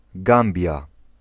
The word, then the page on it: Gambia: [ˈɡæm.bi.ə]